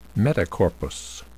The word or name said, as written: metacorpus